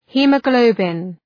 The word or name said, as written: haemoglobin